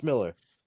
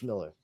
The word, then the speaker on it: Millar: It's pronounced story. Miller. It's pronounced Miller. Fucking crazy Scots, I know, but it's pronounced Miller.